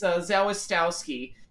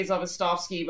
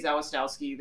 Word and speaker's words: Zawistowski: He left out the first S. It's Zawistowski. I'm sure if you're actually Polish, you would say Zawistowski, but my in-laws, it is a name by marriage. My in-laws say Zawistowski. They're from the Midwest, so they don't pronounce the W's as V's.